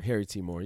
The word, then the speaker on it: Harry T. Moore: Also, at the Harry T. Moore.